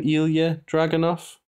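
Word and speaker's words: Ilja Dragunov: Ilya Dragunov? – Yes.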